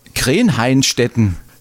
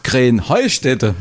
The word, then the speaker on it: Krähenheustätte: Ja, ihr saget immer Krähenhainstetten. Und dabei heißt es doch Krähenheustätte, oder wie? – Krähenheustätte. – Ja, genau, und das ist die Betonung auf dem Heu.